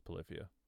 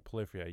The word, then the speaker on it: Polyphia: No, it is Polyphia. Yeah, I thought I was pronouncing that wrong. No, Polyphia, yeah, the crazy shredding, crazy band.